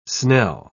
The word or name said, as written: snell